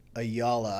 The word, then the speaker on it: Ayala: Ayala. I always tell people it's like y'all with an A at the beginning and at the end.